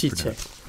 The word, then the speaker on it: Tite: Sorry, you have to pronounce it the way Brazilians Tite.